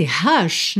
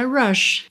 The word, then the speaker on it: rush: Só que esse rush, na verdade, é rush.